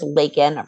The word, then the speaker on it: Lakin: So yes, my name is Lakin, pronounced like bacon.